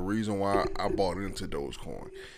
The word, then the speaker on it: Dogecoin: Yeah, Dogecoin.